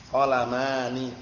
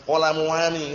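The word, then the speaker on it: Qolamani (قلمان): Qolamani, jangan qolamuani itu enggak. Qolamani, paham ya?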